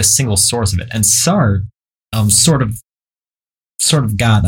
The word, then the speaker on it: Sartre: Sartre? How do you say it? – Jean-Paul Sartre.